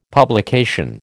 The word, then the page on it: publication: American English